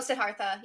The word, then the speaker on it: Siddhartha: Oh, Siddhartha, yeah.